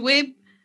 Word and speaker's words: Webb: Y es Webb.